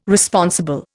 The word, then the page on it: Responsible: Indian